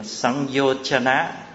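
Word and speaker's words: Saṃyojana: Bây giờ chúng ta sang phần tiếp là Sanyojana. Sanyo bên Thái đọc là Sanyo.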